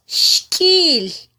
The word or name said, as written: xkyil